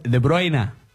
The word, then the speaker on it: De Bruyne: Hace poco yo cedí a la presión de Mario también, porque estábamos haciendo España-Bélgica, y yo le dije, es De Bruyne.